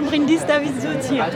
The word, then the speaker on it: Bryndís Davíðsdóttir: Je m'appelle Bryndís Davíðsdóttir.